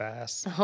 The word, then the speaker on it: Donbass: Donbass. – Okay, thanks. Donbass, region of eastern Ukraine.